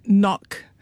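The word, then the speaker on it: NOAQ: Yes, we call it knock, just for fun, just so we can tell each other not jokes.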